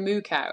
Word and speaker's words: Mukau: They say Waikika Moo Cow, and it's spelled M-U-K-A-U, but it's pronounced Moo Cow.